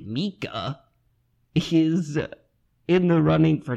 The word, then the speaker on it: Mika: Certainly Micah is... – Excuse me, Mika. – Okay, that's not how that name's pronounced.